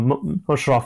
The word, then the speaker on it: Musharaf: So Musharraf Asghar, M-U-S-H-A-R-A-F, Asghar, A-S-G-H-A-R.